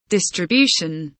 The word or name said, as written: distribution